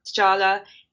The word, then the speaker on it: T'Challa: I thought T'Challa, is that how you say it? Am I making a fool of myself? – That's about right. – The main character, T'Challa.